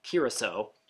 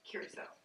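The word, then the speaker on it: Curaçao: But yeah, we looked up Curacao, and it's Curacao. – Curacao.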